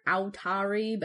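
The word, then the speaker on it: Authari: That's how it's said. It's spelt with an extra U in there, Atari, but yes.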